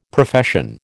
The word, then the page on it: Profession: American English